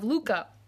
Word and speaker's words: Lykke: It's called The Little Book of Luca. Luca is spelled L-Y-K-K-E.